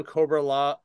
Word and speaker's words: Cobra-La: And he's like, you got to say it right if you're going to do it. I'm like, fine. So I do the full on Cobra-La, like Cobra la la la la la la.